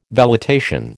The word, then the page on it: velitation: American English